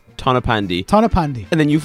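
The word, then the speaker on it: Tonypandy: Tony Pandi. Is that how you say it? – No. – What is it? – Tana Pandy.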